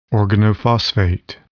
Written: organophosphate

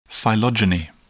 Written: phylogeny

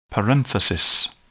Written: parenthesis

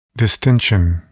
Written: distension

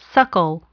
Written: suckle

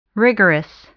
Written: rigorous

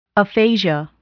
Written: aphasia

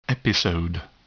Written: episode

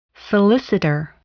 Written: solicitor

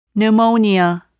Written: pneumonia

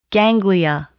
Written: ganglia

